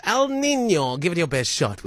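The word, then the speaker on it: El Niño: Joy lamented my pronunciation of El Nino, because that's literally what I said. And that's probably what most people in the world say, El Nino. She said it's El Nino.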